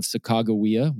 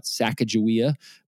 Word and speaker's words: Sacagawea: There was a young teenage Native American by the name of Sacagawea. We were taught Sacagawea, but it's pronounced Sacagawea.